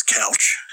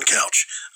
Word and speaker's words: Couch: And I told him it was Couch, which is a one-syllable English name that no one can fucking pronounce. Or somehow no one can deal with. So for the last 30 years of my life, I've introduced myself as Alan Couch, same as the furniture.